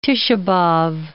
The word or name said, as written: Tishah-b'Ab